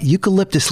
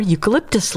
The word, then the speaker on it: eucalyptus: Eucalyptus leaves. – That's correct. The only food koalas eat, which happens to be poisonous to most animals, are eucalyptus leaves.